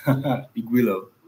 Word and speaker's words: Igwilo: Igwilo.